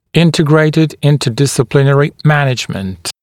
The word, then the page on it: integrated interdisciplinary management: [‘ɪntɪgreɪtɪd ˌɪntəˌdɪsɪ’plɪnərɪ ‘mænɪʤmənt]; [‘интигрэйтид ˌинтэˌдиси’плинэри ‘мэниджмэнт]